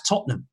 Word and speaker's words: Tottenham: He correctly pronounced Tottenham as Tottenham.